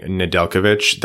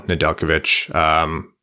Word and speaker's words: Nedeljkovic: Something, a quick note, you'll notice us saying Nedeljkovic. That's just how you pronounce it. I know you people get hung up on the J, but good for you to learn that now. Nedeljkovic.